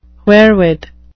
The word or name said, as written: wherewith